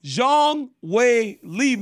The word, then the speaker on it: Zhang Weili: You know, like, that's why everybody mispronounces Zhang Weili name. – I know. – Because you come up here and say Zhang Weili. Carper Jake, you can't be doing this, man. You got to say it right if you on the God mic. Zhang Weili, man.